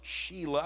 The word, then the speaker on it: Shelah: Shelah, I believe is how you pronounce it.